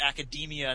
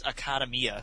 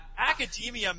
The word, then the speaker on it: Academia: I feel like an idiot now because you pronounce it Academia, and that makes way more sense than the way I pronounce it, which is Academia.